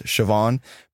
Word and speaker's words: Siobhan: It's spelled Sio Behan. And I was like, what the heck is this name? But that's like one of the more famous Irish names is Siobhan.